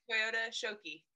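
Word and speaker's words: Toyota Shokki: is it Shiko Tokyo or Toyota? – Toyota Shoki. You're close.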